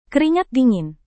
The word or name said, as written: keringat dingin